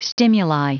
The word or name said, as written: stimuli